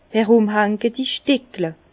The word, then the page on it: erùmhanketi Stickle: Bas-Rhin; Herrlisheim